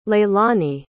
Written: leilany

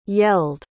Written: yelled

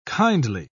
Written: kindly

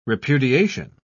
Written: repudiation

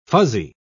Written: fuzzy

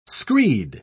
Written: screed

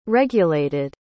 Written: regulated

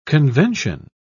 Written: convention

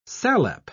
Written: salep

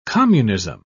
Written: communism

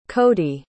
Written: codee

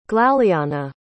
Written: glaleanna

